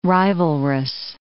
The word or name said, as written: rivalrous